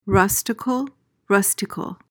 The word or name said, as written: rusticle